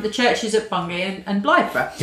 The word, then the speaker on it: Blythburgh: Is that how you pronounce it? Blythburgh? – Yeah. Blythburgh. Yeah.